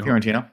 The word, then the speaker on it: Fiorentino: But I want to do Dogma, and I want to replace the leading lady, Linda Fiorentino. I'm probably pronouncing that wrong, but you know Fiorentino. That's right.